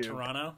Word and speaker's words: Toronto: You don't pronounce the second T. – Yeah, you do. Toronto.